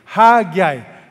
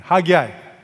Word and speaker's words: Haggai: Haggai. People don't know how to pronounce this, okay? So, I'm going to tell you now. Say that with me. Haggai.